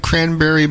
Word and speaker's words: Cranbury: They spell it C-R-A-N-B-U-R-Y, cranberry. A lot of people call it cranberry, in reference to the cranberry bogs.